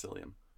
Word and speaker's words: psyllium: Just psyllium. – Psyllium? Mm-hmm. Am I putting the P? – I mean, there is a silent P.